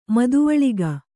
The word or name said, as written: ಮದುವಳಿಗ